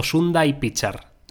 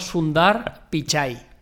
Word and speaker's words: Sundar Pichai: salió el señor, tiene un caso cómo se dice, si Sundar Pichai o Sundai Pichar no, Sundar Pichai. – O sea, Sundar y Pichai, vale.